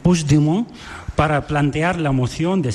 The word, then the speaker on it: Puigdemont: No, Puigdemont. – Puigdemont. Vale. Puigdemont. – Eso es. – Sí, lo dices como francés, así.